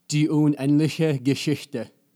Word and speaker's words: die unendliche Geschichte: die unendliche Geschichte.